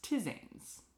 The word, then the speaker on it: tisane: But my favorite fact I found was teas versus tisanes. – Spell that for me. – T-I-S-A-N-E. – Tisane.